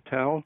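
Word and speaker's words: Tel (תל): And he said the size of it and of the tell, and by the way, you pronounced it correctly, Tal in Arabic, but Tel in Hebrew.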